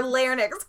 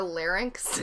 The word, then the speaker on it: larynx: Their larynx collapses. – Do you mean their larynx? – Am I wrong? I didn't know how to pronounce that word. Is it larynx? – Larynx, yeah.